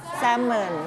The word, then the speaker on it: salmon: อ่านว่า salmon ไม่ออกเสียง l นะ salmon